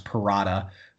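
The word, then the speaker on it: Perrotta: So it's just it's my first name, which is A-N-D-R-E, Andre, and then my last name, which is pronounced Parrotta. So it's A-N-D-R-E-P-E-R-R-O-T-T-A 13.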